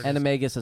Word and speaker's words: animagus: Is it animagus or animagus? Or whatever animagus is how they say it.